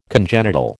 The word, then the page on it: congenital: American English